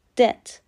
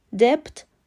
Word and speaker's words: debt: سومین لغت دی e به معنی بدهی تلفظ درست دت. دپت غلطه بی سایلنت و خونده نمیشه حالا بدهکار چی میشه کافی که به دت OR رو اضافه کنیم.